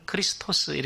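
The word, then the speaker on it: 크리스토스 (Χριστός): 그리스도는 우리나라 발음이고 헬라어는 크리스토스 이렇게 되어 있습니다.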